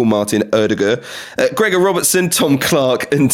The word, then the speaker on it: Ødegaard: Yeah, I've pronounced it right. That's what he says. He's been telling us all how to pronounce his name. Although he did say Ødegaard will do. So maybe you can call him either.